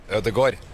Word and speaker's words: Ødegaard: Ødegaard.